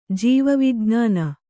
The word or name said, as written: ಜೀವವಿಜ್ಞಾನ